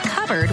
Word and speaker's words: cupboard: When we come back, why it's pronounced covered when it's spelled cupboard? What's up with that?